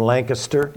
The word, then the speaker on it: Lancaster: Bring greetings from Lancaster.